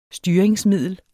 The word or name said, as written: styringsmiddel